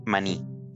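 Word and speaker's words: maní: So you call them mani? – Mani. – With like accent on the end? – Yes.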